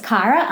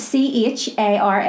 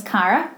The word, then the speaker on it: Chara: Um, my name obviously is Cara, and it's spelled C H A R A. – Mm-hmm. – It looks like Chara or Shara, so I've got that for forty years of my life. People have called me that.